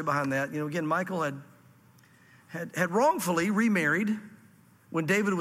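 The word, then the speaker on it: Michal: And it's interesting, when Michael, or however you say it, I think they say Mishal, is how they say it in Israel, Mishal.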